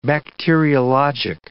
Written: bacteriologic

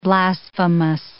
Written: blasphemous

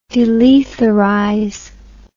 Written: delethalize

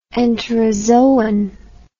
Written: enterozoon

